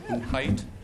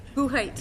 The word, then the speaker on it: Buchheit: Madam Chair, it's actually pronounced Buchheit. – Buchheit. – If you look at it and pronounce it correctly from the spelling, it usually makes me a little nervous. – Buchheit.